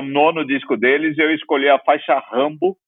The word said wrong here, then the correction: Rumble: Rumble, né? Para não, não vacilar na pronúncia brasileirada, né? Que não precisa.